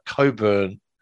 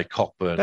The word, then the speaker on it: Cockburn: But I think the way you spelled that, you want me to say Cockburn. – That is how it's spelt. – What, C-O-C-K? – Yes. – Are you talking about the guy that was on the electorate still? – That's how you spell Coburn.